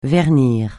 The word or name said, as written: vernir